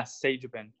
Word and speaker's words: Sagebin: Yeah, Sagebin, yep.